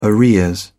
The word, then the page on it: arrears: British English